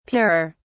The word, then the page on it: purer: British English